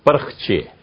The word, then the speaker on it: پرخچے: پرخچے اور پرخچے دونوں میں فرق ہے, ایک صحیح ہے ایک غلط ہے. اور صحیح لفظ پرخچے, ہم نے اپنے دشمنوں کے پرخچے اڑا دیے.